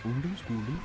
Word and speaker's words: Ungdomsskolen: Then I played you a band called Ungdomsskolen from Norway. Yeah, I think that's how you pronounce it, but it's a bit more like Ungdomsskolen.